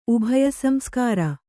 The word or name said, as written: ಉಭಯಸಂಸ್ಕಾರ